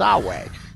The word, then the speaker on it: Samhain: It's in the same place that Samhain is pronounced Sawag.